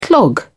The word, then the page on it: clog: British English